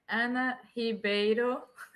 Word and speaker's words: Ana Ribeiro: Ana Ribeiro.